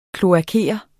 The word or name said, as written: kloakere